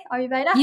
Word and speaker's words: Ayurveda: You know, how does Ayurveda, am I pronouncing it correctly? Ayurveda? – You are. Yeah. Yes, you are.